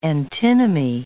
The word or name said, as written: antinomy